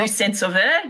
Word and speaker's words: Luz-Saint-Sauveur: Yeah, and from your point of view, being based in, I hope I got my pronunciation correct, Luz-Saint-Sauveur, close enough? – Luz-Saint-Sauveur, yep.